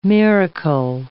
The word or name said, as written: miracle